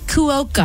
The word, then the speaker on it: Cuoco: And speaking of growing families, Kaylee Cuoco, I always feel like I butcher her last name, but I think that's how you say it.